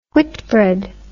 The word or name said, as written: whitbread